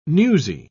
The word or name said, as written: newsy